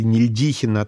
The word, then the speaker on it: Нельдихин: Нельдихин или Нельдихина, там ударение ставят по-разному, но сам он произносил Нельдихин.